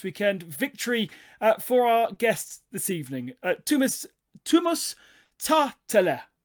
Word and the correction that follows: Tähtälä: So how would you say your name properly? – Tuomas Tähtälä. – Tähtälä. Okay, so the H does actually mean something.